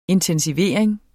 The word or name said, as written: intensivering